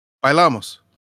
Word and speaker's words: Bailamos: Bailamos.